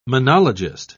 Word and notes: monologist: British English

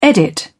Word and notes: edit: British English

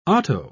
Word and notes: otto: British English

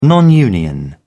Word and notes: non union: British English

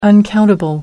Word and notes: uncountable: American English

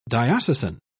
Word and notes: diocesan: British English

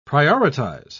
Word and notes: prioritise: British English

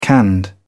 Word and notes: canned: British English

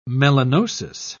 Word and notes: melanosis: British English

